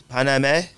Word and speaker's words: Paname: How do you pronounce that? Paname? – It's Paname.